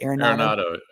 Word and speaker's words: Arenado: Arenado.